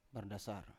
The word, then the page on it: bardasar: Biak